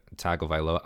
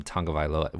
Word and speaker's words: Tagovailoa: Obviously, you got a lot of people's QB1, Tagovailoa. I'm not calling him Tagovailoa. Where's the N in his name? Somebody show me the N in his name. – That's how it's pronounced, dude.